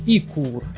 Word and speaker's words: Ecur: Оказывается, есть такая компания, как Ecur, Я правильно говорю? – Ну, судя по написанию, я думаю, что да. – Точнее, Икур, если уж э, более точно.